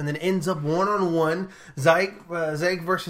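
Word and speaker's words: Ziyech: So basically, Zayek, I can't pronounce his name, Zeke. – Zeke? – I don't know how to say it. But it's Zayek. It's all good.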